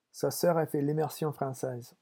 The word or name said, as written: sa soeur